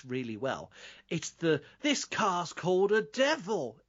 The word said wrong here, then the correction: Deville: Deville, mate. It's pronounced Deville.